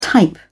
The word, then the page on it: type: British English